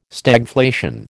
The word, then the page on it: Stagflation: American English